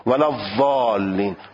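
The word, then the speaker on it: الضالین: گفت معروف است که تلفظ زاد به نحو متداول بین عراقی ها ولذالین از نوک زمان و مصری ها ولذالین صحیح نیست و صحیح تلفظ اهل حجاز است. معتقدند از نظر تاریخ میگن حجاز اینجور میخونن ولذالین، همون از حروف رخوهی که حاشیه زبانو به بین دندانهای آسیا میچسبانند و حالت استطاله داره، ولذالین. بعد ولذالین اینو میگن صحیح این تلفظه.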